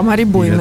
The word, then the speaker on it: Мари Бойне: Это Сергей Старостин, Мари Бойне Персен, если я правильно произношу ее. – Да, Мари Бойне.